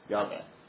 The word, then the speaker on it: Yahweh: Jehovah has been rendered in the English Bible L-O-R-D, all capitals. And it comes from the Hebrew tetragrammaton, YHWH, or Yahweh, which is pronounced as Yahweh. Note there are no J's in Hebrew.